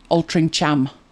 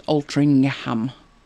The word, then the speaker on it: Altrincham: Oh, everybody gets Altrincham wrong. Altrincham. But it's actually pronounced as if there's a G in it. Altrincham.